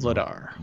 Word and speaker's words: Vladar: Their tandem is Jacob Markstrom and Dan Vlader. Or am I saying that right? Vladar? I don't Vladar. – Know. Vladar.